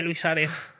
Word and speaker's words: Suárez: Es que podéis buscar frame a frame como hace Luis Suárez.